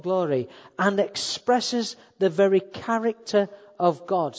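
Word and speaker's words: character (χαρακτήρ): It's the word character. And that's how you pronounce it, character.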